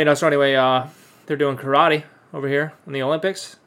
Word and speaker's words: karate: Or as it's pronounced in Japanese, karate, which means empty hand.